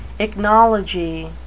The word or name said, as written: ichnology